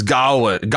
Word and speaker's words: Gawain: Garwin, I always pronounce this Gawain. – Gawain. I always pronounce it Gawain too, but it's Gawain. Gawain.